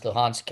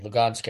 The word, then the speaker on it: Luhansk: pronounced Luhansk in Ukraine, Lugansk in Russia. That letter is an H or a G, depending on the language you're speaking.